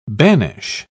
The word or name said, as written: banish